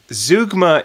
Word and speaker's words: zeugma: It's zeugma. – Zeugma. Zeugma is a new fusion form of exercise where you stand in a lion enclosure and try not to get eaten.